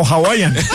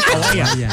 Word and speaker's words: Hawaiian: No, Hawaiian. Hawaiian. Al principio lo dijo bien.